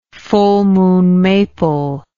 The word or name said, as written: full moon maple